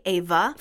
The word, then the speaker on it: Éabha: Um, one of the more popular names is Ava, but it's spelled E Fada A B H A Ava for girls.